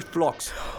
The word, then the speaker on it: Phlox: Sure, that's going to be Special Ops AD Phlox. – Oh, for the love of... – Rhymes with box.